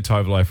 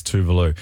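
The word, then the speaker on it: Tove Lo: I said Tovlo for ages, but when I got right into her, I realised it's Tuvalu. This is so good. It's brand new from Tuvalu.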